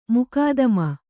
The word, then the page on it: ಮುಕಾದಮ: mukādama